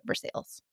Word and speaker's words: Versailles: And no, we don't pronounce it the way it should be pronounced, Versailles. We are not that fancy in Indiana. And we also like to put a little twang on everything. So the town, we call it Versailles.